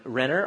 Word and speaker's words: Renner: My name is Nathan, and I'm Renner, R-E-N-N-E-R.